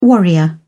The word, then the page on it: warrior: British English